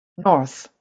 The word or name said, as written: North